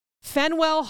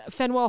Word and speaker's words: Faneuil: This is like mentioned a lot, so I didn't know how to say it because it is spelled F-A-N-E-U-I-L. Fenwell.